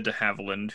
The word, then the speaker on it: de Havilland: De Havilland is how you say it. It's not a hard one.